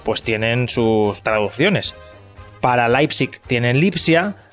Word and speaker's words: Leipzig: Leipzig, ¿eh? como dice José Ramón de la Morena, es Leipzig, Leipzig también pronunciado en castellano rudo.